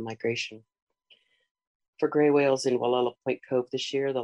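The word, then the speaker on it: Gualala: My first preferred site is the Bluff Trail in downtown Wallala, Mendocino County, California. We pronounce it Wallala. Wallala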